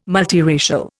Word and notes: multiracial: Indian

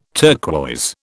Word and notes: turquoise: British English

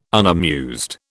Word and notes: unamused: British English

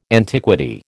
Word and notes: antiquity: American English

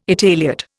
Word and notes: Italiot: Indian

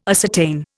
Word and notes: ascertain: Indian

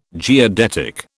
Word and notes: geodetic: British English

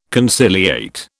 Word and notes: conciliate: British English

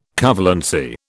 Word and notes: covalency: British English

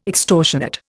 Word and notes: extortionate: Indian

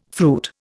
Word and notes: fruit: Indian

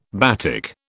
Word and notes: batik: British English

